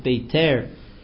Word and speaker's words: Beitar: We mentioned last week that Rav Shri Raigon mentions the destruction of Beitar, or some pronounce it Beiter.